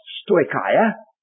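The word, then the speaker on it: stoikia (στοιχεῖα): Now this word principle is in the Greek language pronounced stoikia, and it simply means it's a part of the verb to walk, but it means little steps.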